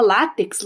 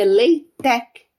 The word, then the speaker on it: LaTeX: A gente fala látex, latex aqui no Brasil, né? Porque se escreve L-A-T-E-X, mas a pronúncia correta é Leitec, né?